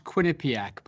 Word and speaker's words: Quinnipiac: uh, it's pronounced Quinnipiac, by the way, Quinnipiac Paul. I grew up uh, near the Quinnipiac River in Connecticut.